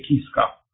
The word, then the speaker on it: Kiska: Pronunția în limba engleză are numele insulei este Kiska. Acum nu știu băștinașii cum pronunță numele, dar deocamdată rămânem la Kiska Island.